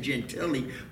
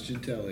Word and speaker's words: Gentile: I think I finally got to where I'm pronouncing it Gentile, but for the longest time I wanted to pronounce it Giovanni Gentile. – No, but it's Gentile, yeah. – It's spelled Gentile, by the way. It's spelled the same as the word Gentile.